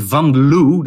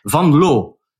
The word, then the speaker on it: Van Loo: If you want to pronounce my name, you say Van Loo. It's not nice in English, but it, you should say Van Loo.